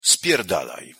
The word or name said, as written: spierdalaj